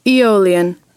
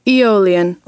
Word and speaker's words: Aeolian: Aeolian. – Did you get that? – No, I didn't quite get that. – Okay, listen up. – Aeolian.